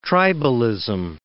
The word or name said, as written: tribalism